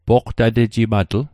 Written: poqtatejimatl

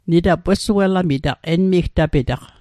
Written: wesuelamit

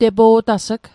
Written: tepo'tas'g

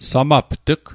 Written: samapt'g

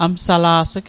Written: amsala's'g

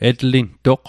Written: etlintoq

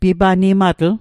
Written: pipanimatl

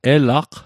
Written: elaq

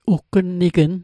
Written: uggnnignn

